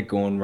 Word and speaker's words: Gawn: No wonder Gawn rhymes with form, because that's what he's in.